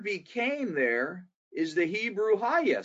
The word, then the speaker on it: hayah: Okay, that word was in Genesis chapter 1, verse 2 is the Hebrew word haya, H-A-Y-A-H, if we're spelling it phonetically.